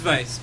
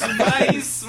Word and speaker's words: Weisz: Again, it's Rachel Weisz. – Yeah, it is Weisz, by the way. – I am Jamaican. I'll pronounce it... – Rachel Weisz. – No, it's Weisz, motherfucker.